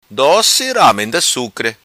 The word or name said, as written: Sucre Doci Ramenda